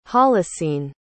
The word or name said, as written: holocene